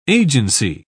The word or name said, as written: agency